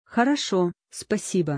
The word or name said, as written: Хорошо, спасибо